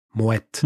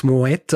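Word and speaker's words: Moët: Eine Sache, die ich nämlich auch falsch gemacht habe in der ursprünglichen Folge, ich habe es Moe ausgesprochen. Ja, ich habe gedacht, ne? Moet. Aber man spricht es Moet aus.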